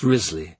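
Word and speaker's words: Wriothesley: Towards the end of the alphabet comes Thomas Riothesley, pronounced Risley.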